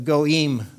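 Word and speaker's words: goyim: The goim, the goi is who they were, but in the plural form, it's goim. You'd spell it G-O-I-M, okay? Pronounce it go-im.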